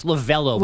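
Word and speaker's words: Lovullo: Well, he barely made the roster, and it was Terry Lavullo. I never pronounce his name right. Anyway, it's his fault. It's Lavello, but there's a U in there that you don't pronounce and it throws you off.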